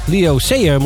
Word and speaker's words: Leo Sayer: Sayer. Leo Sayer moeten we zeggen.